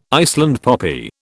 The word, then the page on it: Iceland poppy: British English